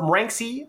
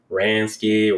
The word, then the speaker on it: Ranksey: it's a rare occasion that someone pronounces the name right. I get Ransky, or sometimes people just don't even try to attempt it.